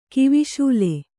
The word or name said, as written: ಕಿವಿಶೂಲೆ